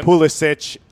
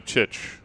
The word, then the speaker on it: Pulisic: Pulisic, I guess it would be the Croatian pronunciation most likely. – So he's not, there's no Chich. – Yeah, he doesn't seem to go by that.